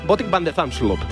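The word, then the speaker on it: Botic van de Zandschulp: Jodar juega a las 6 de la mañana contra Botic Fan de Zansrubo. ¿Lo he dicho bien? Bueno, parecido. En neerlandés, Botic van de Zamsloop.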